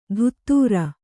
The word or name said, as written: ದುತ್ತೂರ